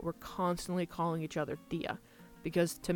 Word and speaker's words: tía: But tío is spelled T-I-O, and tía is spelled T-I-A, and the I has an accent on it in both of those words. Tío and tía.